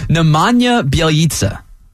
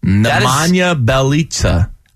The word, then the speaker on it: Nemanja Bjelica: It's such a weird pronunciation because the way they hyphenetically did it was B Y E L L as the first syllable. – Nemanja Bjelica. – It's very weird to try to put that as Biel.